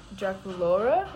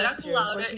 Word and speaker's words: Draculaura: Draculaura? Like Laura? – And Draculaura. Much better, guys. Yeah, Draculaura is superior.